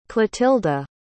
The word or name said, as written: Clotilda